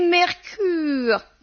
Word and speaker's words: Mercœur: Et puis, assez vilain pour une femme chic, ça s'écrit Mercœur. – « Il faut prononcer Mercure, » disait-elle, la bouche en cul de poule.